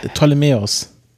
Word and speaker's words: Ptolemäus: Auch noch, das P ist stumm. – Das ist wie bei Ptolemäus.